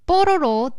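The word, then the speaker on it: Pororo: Well, Loopy is one of the main characters from the South Korean cartoon series called Bororo, the Little Penguin. – Well done. – Yeah, that's Korean pronunciation, by the way.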